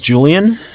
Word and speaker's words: Julian: Okay, it's my name's Julian, J-U-L-I-A-N. – Okay. – I'm calling from Bank of Cardiff. Cardiff is spelled C-A-R.